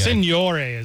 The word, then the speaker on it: Signore: Signore is like Italian Signore? – Version. We like Americanized.